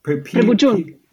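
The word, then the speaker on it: preboggion: I'm probably going to pronounce it wrong, and I apologize. The preguin, is that correct? – Prebujun. – Prebujun.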